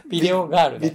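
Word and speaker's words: 電影少女: ビデオガールね。ビデオガールか。 – 田園少女と書いてビデオガールと読むあの漫画ね。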